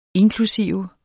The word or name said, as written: inklusive